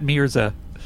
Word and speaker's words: Mirza: It just sounds like you're saying my last name wrong. – Yeah, Matt Mirza.